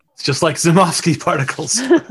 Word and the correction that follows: Minovsky: I think it's Minovsky particles, right? – Wait.